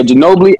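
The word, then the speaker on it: Ginóbili: Yeah, Ginobili.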